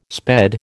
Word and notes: sped: American English

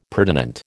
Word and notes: pertinent: American English

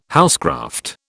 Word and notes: housecraft: British English

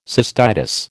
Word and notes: cystitis: American English

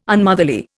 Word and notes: Unmotherly: Indian

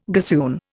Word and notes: gossoon: Indian

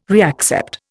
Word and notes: reaccept: Indian

